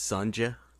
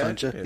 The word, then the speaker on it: Sonja: I believe it's pronounced Sonja. – Yeah, Sonja, yes.